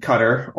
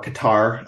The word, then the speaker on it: Qatar: is because it's being hosted in Qatar, or Qatar. I believe both pronunciations are acceptable.